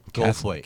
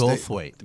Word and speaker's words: Goldthwait: Bobcat, how do you say his last name? – Goldthwait. – Can't read my writing. – Goldthwait? – Yeah. Born in this Goldthwait. – Day. Goldthwait. Born.